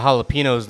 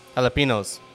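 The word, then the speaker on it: jalapeños: That's how you pronounce it. Jalapenos. – Jalapenos? – Jalapenos. – I thought it was jalapenos.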